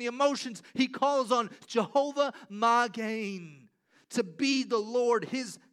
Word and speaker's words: Jehovah Magen: Jehovah Magen is the way it's pronounced in the Hebrew.